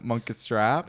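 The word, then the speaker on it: Munkustrap: Number nine, Monka Strap. Mm-hmm. Is that how you say it? – Yeah.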